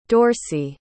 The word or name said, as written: dorsey